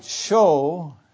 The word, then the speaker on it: shew: but it was that they might show, as he says, might show this unto you. Spelled in the Authorized Translation, shew, S-H-E-W, but it needs to be pronounced show.